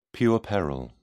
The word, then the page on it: puerperal: British English